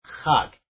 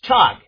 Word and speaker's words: chag: In the Strong's Concordance or something, they'll spell it C-H-A-G, but it's not. It's pronounced K-H-A-G, hug. If you see C-H-A-G, you're going to think it's chug, and it's not. Okay, it's hug.